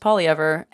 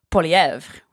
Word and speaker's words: Poilievre: No, I think someone said to me that out West, the name is pronounced Polyever. And if you look at it and you really get English about it, that's kind of how you say it. If you're not going to say Polièvre, which I'm not going to say.